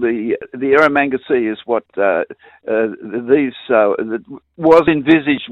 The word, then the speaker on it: Erromanga: Yes, and I believe it's called the Erromanga Sea, did I say that right? – The Erromanga Sea is correct,